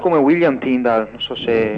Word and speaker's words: Tyndale: La pronuncia è Tyndall, penso si scriva Tyndale.